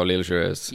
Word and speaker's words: L'Île Joyeuse: Oh, L'Île Joyeuse.